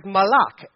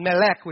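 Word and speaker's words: malak (מלאך): The Old Testament word, because the Old Testament was translated for the most part from Hebrew, and the Hebrew word is malak, or I probably pronounced that wrong, but it's not melek, which is king. It's M-A-L-A-K.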